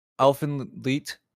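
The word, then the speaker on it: Elfen Lied: Yeah, Elfenleet, as we found out, it's pronounced.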